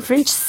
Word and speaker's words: French: Y es que French, ¿cómo se dice? Sí, French.